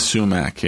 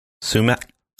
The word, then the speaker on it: Sumac: Ima Sumac, yeah. Technically, the last sound is pronounced as one of like a click in the back of the throat. – Sumac.